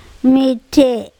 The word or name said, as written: miTHe